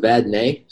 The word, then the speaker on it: Vadnay: It's pronounced Vadnay, so it's like Vad-Nay.